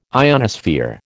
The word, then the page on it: ionosphere: American English